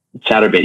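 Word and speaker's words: Chaturbate: Chartubate? I don't know how to pronounce it. Chatterbait. Yeah, there you go.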